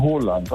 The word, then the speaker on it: Haaland: Er wird ausgesprochen Haaland, also mit O.